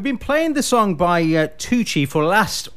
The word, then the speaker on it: Toochi: Yes, no, it's Toochi, that's correct.